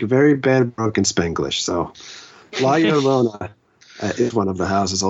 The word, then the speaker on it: La Llorona: But the other one is La Llorona. And I'm saying that correctly this time, everybody. – La Llorona. – Yeah, because I made the mistake of saying La Llorona on YouTube.